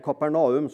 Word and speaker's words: Kapernaum: Så kommer han hjem til Kapernaum. Eller Kapernaum, som noen mener at det skal uttales.